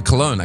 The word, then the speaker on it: Cologne: In, in English, we say Col- uh, Cologne, actually.